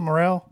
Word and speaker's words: Morel: May 10th, keeping Morel, is that how you pronounce it, Morel? – Mm-hmm.